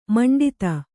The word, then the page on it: ಮಂಡಿತ: maṇḍita